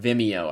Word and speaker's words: Vimeo: No, it's on Vimeo, I think. I think that's how you say it. V-I-M-E-O, Vimeo.